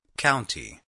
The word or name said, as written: county